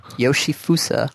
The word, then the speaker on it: Yoshifusa: Yoshifusa Hayama.